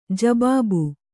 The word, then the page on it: ಜಬಾಬು: jabābu